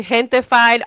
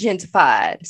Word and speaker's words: Gentified: Can you say it again? That's how it's said? – Gentified. – Oh, I thought it was gentrified.